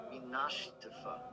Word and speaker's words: Minashtifa: It's minshtifa. It's not going to be where it fell. – It's pronounced Minashtifa. – Minashtifa. Okay. Minashtifa.